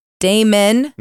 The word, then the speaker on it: Damon: Damien's son? – Damon. – No. Damien. – If you don't start saying it right, I'm not going to do this podcast.